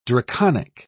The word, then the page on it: draconic: British English